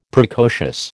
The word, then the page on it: Precocious: American English